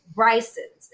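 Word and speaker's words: Brigtsen's: Bryson's. It's the one that just for the listeners, it looks like Brixton's. So you're going to look it up online, Brigston apostrophe S, pronounced Bryson's.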